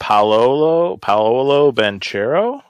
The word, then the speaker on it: Banchero: For number one, we got Paolo Banchero. Was that how you said? Did I just absolutely butcher that name? – I think power forward from Duke. – You did pretty well there, guy.